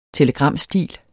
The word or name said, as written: telegramstil